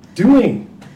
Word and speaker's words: Dewing: Dewing.